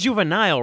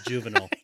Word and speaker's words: Juvenal: I was about to say, like you pronounced Juvenile wrong. – Well, the poet's name is Juvenal. Juvenile is the rapper.